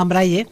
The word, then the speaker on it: Braille: Es que, bueno, teníamos un pequeño debate aquí sobre cómo se pronunciaba lo del sistema Braille, y es efectivamente así en español, ¿no? – Sí. La fórmula francesa pues sería con la E, pero bueno, nosotros lo hemos adaptado, castellanizado y se escribe Braille.